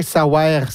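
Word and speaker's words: Saouère: Je dis bien « savoir », c'est parce que c'est éplé S-A-O-U-E, accent grave, R-E. Mm-hmm. Donc, c'est éplé de manière phonétique, comme on le prononce.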